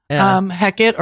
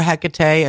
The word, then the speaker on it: Hecate: Heckett or Hecate as the witches pronounce it.